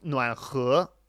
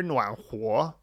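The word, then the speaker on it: Nuan Huo (暖和): I hear Nuan Huo way more often. Nuan He is, I don't think I can recall anybody ever saying it that way. He, of course, is the primary pronunciation of that particular character, but usually people will refer to the air or the environment being warm as Nuan Huo, Huo.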